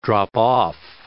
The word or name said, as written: drop off